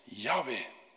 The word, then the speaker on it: Yahweh: Vandaag de dag eh, zijn de meeste Bijbelgeleerden het over eens dat het tetragram waarschijnlijk moet uitgesproken worden als Yahweh.